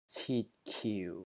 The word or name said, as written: CIT3 KIU4